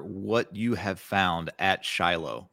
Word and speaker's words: Shiloh: And I know you also were the director of excavation at Shiloh.